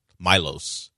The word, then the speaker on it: Milos: Milos is what he calls him.